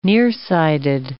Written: nearsighted

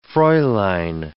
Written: fraulein

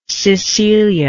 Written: Cecilia

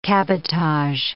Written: cabotage

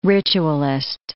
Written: ritualist

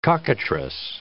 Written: cockatrice

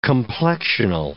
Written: complexional

